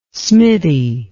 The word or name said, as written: smitty